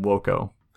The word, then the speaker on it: voco: Or sorry, V is pronounced W. So paredam voco.